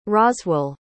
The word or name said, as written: Roswell